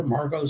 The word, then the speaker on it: Margot: Margot spelled with a T on the end, and you will hear a lot of people who aren't Margot talk about the impact that her writing had on them.